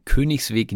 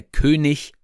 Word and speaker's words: König: Und wie ihr hören könnt, sage ich Königsweg, ich sage nicht Königsweg, denn ein IG am Ende einer Silbe wird CH ausgesprochen. Es gibt da auch Ausnahmen von, aber der König wird immer König gesprochen.